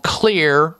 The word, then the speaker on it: Xlear: You need to find out about what is called Clear Rescue Nasal Spray. Clear is spelled X-L-E-A-R.